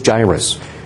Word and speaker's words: Jairus: I think the correct pronunciation is Jairus based on the spelling. But when I was a kid, I put the I after the R, and I always called the guy Jairus. And because of that, that just sticks in my head, so I might pronounce it wrong, but I know better. It is Jairus.